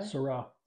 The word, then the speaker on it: Sirah: How do you say it again? Sirah? – Sirah. – Sirah? – Yep.